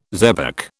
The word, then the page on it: xebec: British English